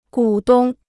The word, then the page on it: 股东: gǔ dōng